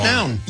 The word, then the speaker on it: McNown: McNown. – You know, I always say, because it's M-C-N-O-W-N.